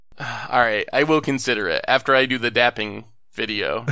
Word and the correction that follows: dabbing: Did I say that right? – Dabbing. – Dabbing. There we go.